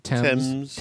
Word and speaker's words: Thames: Is that how you say it? Thames? – Yeah, Thames.